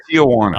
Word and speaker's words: Tijuana: I was referring to the way you pronounce T-I-J-U-A-N-A. – Tijuana.